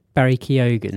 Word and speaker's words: Barry Keoghan: Barry Keoghan.